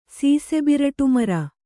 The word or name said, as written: ಸೀಸೆಬಿರಟುಮರ